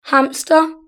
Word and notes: hamster: British English

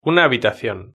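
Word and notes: una habitacion: Spanish (Spain)

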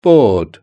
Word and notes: board: British English

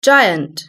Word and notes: giant: British English

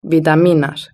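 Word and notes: vitaminas: Spanish (Spain)

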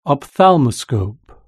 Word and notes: ophthalmoscope: British English

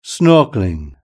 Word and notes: snorkelling: British English

